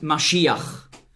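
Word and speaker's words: Maschiach (משיח): In meiner Bibel steht Messias, weil im Grundtext hier das Wort Maschiach, so ist die hebräische Aussprache, zu finden ist.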